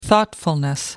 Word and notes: thoughtfulness: British English

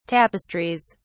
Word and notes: tapestries: British English

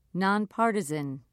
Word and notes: non-partisan: British English

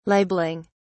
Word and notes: libeling: British English

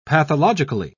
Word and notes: pathologically: British English

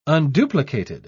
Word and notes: unduplicated: British English

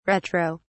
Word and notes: retro: British English

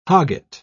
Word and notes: hogget: British English

